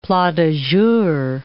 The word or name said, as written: plats du jour